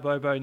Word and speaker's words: Bobo: I actually think, watch out for Bobo. – Yeah. – Is that how you pronounce it? Bobo? Or yeah, Bobo. I mean.